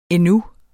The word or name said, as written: endnu